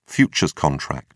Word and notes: futures contract: British English

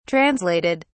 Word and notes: translated: British English

